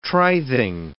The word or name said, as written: trithing